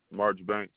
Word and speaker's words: Marchbanks: My name is Harlem March Banks.